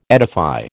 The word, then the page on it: edify: American English